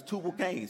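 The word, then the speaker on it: Tubal-cain: Then he had another son, Cain Tubal. Am I saying that right? Say it back. It's Tubal Cain, excuse me.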